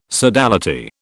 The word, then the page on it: sodality: British English